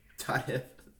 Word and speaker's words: Heap: Is it Todd Heap? Todd Hep? – Todd Hep. – Todd Hep? – No, Heap is the right way to say it.